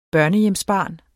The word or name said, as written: børnehjemsbarn